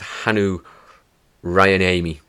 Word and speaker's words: Hannu Rajaniemi: So many thanks to Rupert Degas, who read the book, and with his pronunciation of the name, and it's Hannu Rajaniemi.